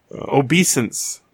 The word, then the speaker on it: obeisance: Some guy at the, you know, garage, he says, yeah, we're going to I have to say it right, an orgy later. Obeisance. – Okay, now I know the word. – Yeah, I'll say it properly from now on.